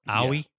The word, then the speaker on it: Aoi: Her name is like A-O-I, but it's pronounced like Aoi.